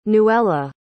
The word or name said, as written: nuela